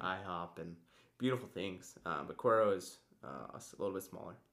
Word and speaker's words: Cuero: Cuero, Texas.